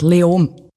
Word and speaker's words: Leoben: Spricht man es Leoben aus? Wie spricht man das überhaupt aus? – Eigentlich fast kein Konsonant. Leom.